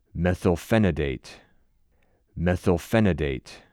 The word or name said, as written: Methylphenidate